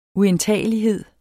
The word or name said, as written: uindtagelighed